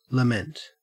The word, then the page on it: lament: Australian English